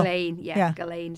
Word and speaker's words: Ghislaine: Ghislaine is how you say it.